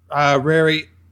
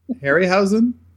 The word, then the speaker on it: Harryhausen: They go full, Rary. Oh God, I can't say his name. Harry. Harryhausen.